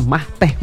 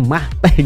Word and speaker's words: mate: apa, yaitu Mah Teh. Nah, unik banget kan tuh teman Pro 2, namanya Mah Teh, minuman namanya Mah Teh gitu. Tulisannya sih Mate ya, MATE, tapi dibacanya adalah Mah Teh gitu.